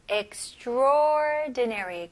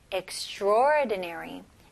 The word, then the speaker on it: extraordinary: 하지만 발음할 때는 extraordinary. 그래서 A를 그냥 아예 무시를 하시는 게 좋아요. extra보다는 그냥 바로 O로 넘어가서 extraordinary.